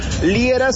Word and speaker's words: Liera: Además, recuerden también, importante, tú armas la ruta del amor en mis redes sociales, me puedes buscar por mi nombre, Fernando Liera, se escribe L-I-E-R-A,